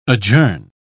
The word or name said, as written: adjourn